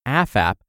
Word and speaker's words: AFAP: AFAHP, pronounced AFAP, is a popular acronym.